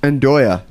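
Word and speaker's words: nduja: And I'm sorry, there's something I don't recognize, and I'm going to butcher it. Nudja? – Ndoya. – Ndoya. Nailed it.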